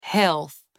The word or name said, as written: health